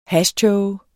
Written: hashtåge

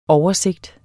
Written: oversigt

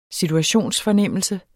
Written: SITUATIONSFORNEMMELSE

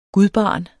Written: gudbarn

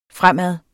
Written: fremad

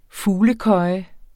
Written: fuglekøje

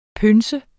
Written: pønse

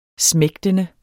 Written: smægtende

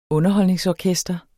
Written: UNDERHOLDNINGSORKESTER

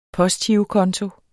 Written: postgirokonto

